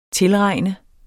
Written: tilregne

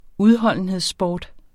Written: udholdenhedssport

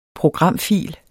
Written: programfil

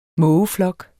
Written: mågeflok

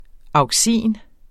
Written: auxin

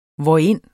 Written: hvorind

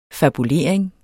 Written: fabulering

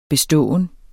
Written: beståen

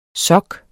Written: sok

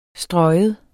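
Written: stryge

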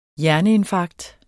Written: hjerneinfarkt

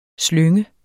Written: slyng

